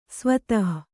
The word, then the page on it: ಸ್ವತಃ: svatah